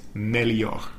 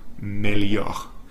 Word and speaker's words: melhor: Mejor is melhor, melhor.